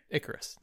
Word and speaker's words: Ikris: Icarus. That's Richard Madden's character's name. – Okay. – It's spelled I-K-R-I-S.